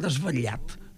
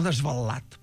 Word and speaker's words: desvetllat: O desvetllat, perquè es pot pronunciar de totes dues maneres, eh? Desvetllat o desvetllat. Desvetllat es pronuncia, doncs, a la zona sud de les Terres de l'Ebre, País Valencià i les Illes.